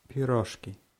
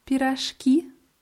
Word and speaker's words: пирожки: Тебе понравились пирожки? – Ja, die Betonung ist auf i auf Russisch. Тебе понравились пирожки?